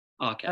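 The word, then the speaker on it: Arket: It's spelled like market, but without the M. Arquette.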